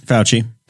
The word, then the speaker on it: Fauci: Fauci.